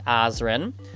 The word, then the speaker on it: Azren: It is at Polyglot Azren. That is spelled P O L Y G L O T, A Z or Z depending on your country, R E N.